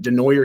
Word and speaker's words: DeNooyer: And when a dealership decides, like, host a trunk or treat, which is what DeNoyer, I think. I'm sorry, I might be mispronouncing it. DeNoyer Chevrolet. – You got it. Nailed it.